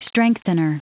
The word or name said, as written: strengthener